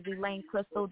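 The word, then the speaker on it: Elaine Kristal: My name is Elaine Crystal.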